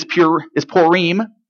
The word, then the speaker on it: Purim: Hebrew pronunciation is Purim. Okay, Purim is how you say that in Hebrew.